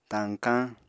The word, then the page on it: tañkañ: Apatani